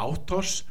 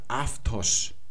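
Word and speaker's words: Aftos (αὐτός): Man sagt also nicht mehr Autos, sondern man sagt Aftos.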